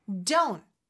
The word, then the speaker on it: don't: Don't. T 소리가 안 나죠. Don't. 물론 don't. T 발음 내도 되지만 보통은 그냥 don't. 이렇게 끊어주는 느낌으로 해주시면 돼요.